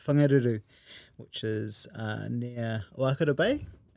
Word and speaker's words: Whangaruru: Whangaruru is about an hour north of Whangarei on the east coast.